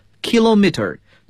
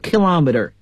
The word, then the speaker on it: kilometer: 说到这个平方公里啊，一般在英国呢，我们愿意英国英语中呢愿意把这个词呢读作 kilometer，但是在美国英语中要读作 kilometer kilometers。